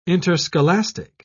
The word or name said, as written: interscholastic